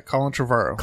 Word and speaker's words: Colin Trevorrow: So as a follow-up to this, how do you say his name? Trevorrow? – Yeah, Colin Trevorrow.